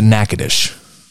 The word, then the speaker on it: Natchitoches: On the Louisiana side, it's spelled similarly, but for whatever reason, maybe it's the Cajuns over there, they screwed it up. They pronounce it Natchitoches.